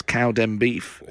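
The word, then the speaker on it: Cowdenbeath: Bob has said Scottish team Cowden Beef, and then he's put in brackets Cowden Beef. – He's put the pronunciation in case because we know it.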